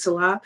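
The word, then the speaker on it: Nselaa: My name is spelled a little differently. It's N-S-E-L-A-A. It's Salah. It's the N is silent. So N-S-E-L-A-A-W-A-R-D.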